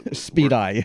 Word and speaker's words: SPEDI: Of course, there's an acronym, Tom, SPEDI or SPEDI. – SPEDI. – SPEDI, yeah. – Rhymes with Jedi.